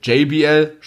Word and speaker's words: JBL: weil GBL ist ja, äh, ich sag, ich sag wieder GBL, JBL spricht man es richtig ja.